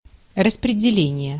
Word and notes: assignment: American English; /əˈsainmənt/

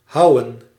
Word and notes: houwen: /ˈɦɑu̯.wə(n)/; /ɦʌu.ʋǝ:/

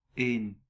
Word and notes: een: /eːn/; /ən/; [eɪ̯n]

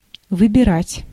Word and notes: выбирать: [vɨbʲɪˈratʲ]

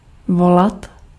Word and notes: appeler: France

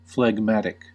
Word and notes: phlegmatic: /flɛɡˈmætɪk/

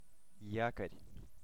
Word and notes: якорь: /ˈjаkərʲ/; jakor

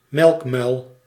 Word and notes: béjaune: Bruxelles, Belgique; [be.ʒon]

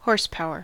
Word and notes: horsepower: /ˈhɔɹs.ˌpaʊ.ɚ/; [ˈhɔɹs.ˌpaʊ.ɚ]; /ˈhɔːs.ˌpaʊə/

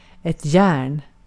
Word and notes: järn: /jɛːrn/

